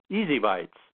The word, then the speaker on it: ESI Bytes: You can also remember the show and website phonetically as Easy Bytes of ESI Information, which is how it sounds out.